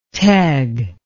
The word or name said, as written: teg